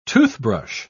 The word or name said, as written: toothbrush